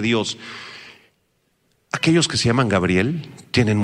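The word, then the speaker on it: Gabriel (גבריאל): Subrayen la palabra hebrea para Gabriel, se escribe exactamente igual que en español, pero con acento en la E. Gabriel es en hebreo, Gabriel, que significa guerrero de Dios, guerrero de Dios u hombre de Dios.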